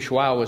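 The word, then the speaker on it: João: And so when we have the quiet time, when I was in Abidjande with Medium Shuao, is what he refers to himself as, which is J-O-A-O, pronounced Shuao, it's mostly meditation.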